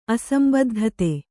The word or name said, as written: ಅಸಂಬದ್ಧತೆ